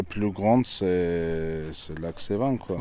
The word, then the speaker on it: Sevan: c'est bien prononcé, le lac Sevan.